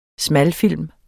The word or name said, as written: smalfilm